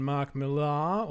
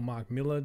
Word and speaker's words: Millar: All right, I'm going to take it over here now, and I am talking about Superman Red Son, Son, S-O-N. This is published in 2003, written by the well-renowned Mark Millar, or Mark Miller, depending on how you say it, art by Dave Johnson and Killen Plunkett, I think.